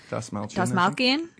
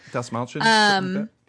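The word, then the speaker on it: Dastmalchian: But yeah, I mean, David Dasmalchian. I don't know how to say his last name. – Dasmalchian. Yeah, so Dasmalchian.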